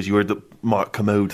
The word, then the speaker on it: Kermode: I think it's Kermode, yeah. – So you put the emphasis on the Ker so it doesn't sound like a Kermode.